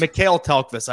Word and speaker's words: Mikael Tellqvist: Aubin, and Michael Tellqvist. Those are the three. – All three goalies. – Mikael Tellqvist. I've been pronouncing his name like a dumb Canadian this entire time.